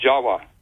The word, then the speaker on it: Java: Furthermore, appending the suffix of T-I-S, the word Atlantis, it also is spelled T-I-V or pronounced T-I-W. These are pronounced as W's in Dravida, such as in Java. The word in Java, the island of Java, you know, is pronounced Jawa. Actually, Indonesians will pronounce it Java, not Java like we do.